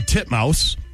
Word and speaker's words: Titmus: Pronounce it the way it's spelled. – Ariane Titmus. Titmus. – There you go. – Is that how you say it? – That is legitimately how you say it.